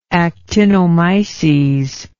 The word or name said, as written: actinomycetal